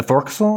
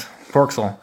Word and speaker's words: forecastle: Oh yeah, it's spelled like forecastle. It's like the forecastle. – Forecastle. Yeah. – Forecastle. Yeah. – Forecastle. – They kept on calling it that.